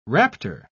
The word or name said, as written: raptor